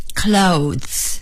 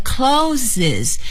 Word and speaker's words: clothes: 衣服，clothes, clothes, clothes。请朋友们一定要注意，好多朋友呢看到这个 clothes 是以 es 结尾的，就念成 clothes，这是错误的，非常错误，非常错误，千万别学刚才说的那个啊。